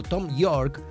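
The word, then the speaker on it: Thom Yorke: Claro que sí, porque un 7 de octubre, pero del año 1968, nacía Tom York, o Tom York, ahí está, esa es la pronunciación exacta,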